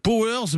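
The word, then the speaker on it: PowerZ: Et ben voilà, ça s'appelle Powers, mais ça s'écrit alors Power Z, voilà, pour ceux qui veulent y avoir accès, jeux vidéo éducatifs donc, et puis ludique, vous l'avez entendu, conçu pour les enfants.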